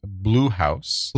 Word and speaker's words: Bluhauz: So the band's name is Blue House. But it's spelled Bluhauz or Z if you are Canadian.